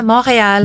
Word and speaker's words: Montreal: Yeah, Montreal.